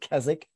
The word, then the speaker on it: Keswick: It's spelled Keswick, but I learned very quickly it's said, you pronounce it Keswick. So a little language lesson there.